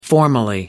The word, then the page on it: formerly: American English